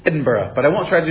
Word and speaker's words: Edinburgh: By the way, when you see Edinburgh, G-H at the end, you say Edinburgh, not Edinburgh. Edinburgh, that's how they say it.